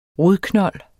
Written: rodknold

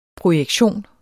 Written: projektion

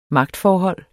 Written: magtforhold

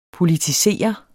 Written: politisere

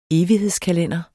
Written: evighedskalender

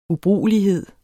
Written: ubrugelighed

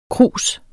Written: krus

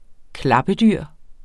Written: klappedyr

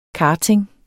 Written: karting

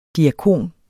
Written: DIAKONEN

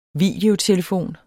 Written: videotelefon

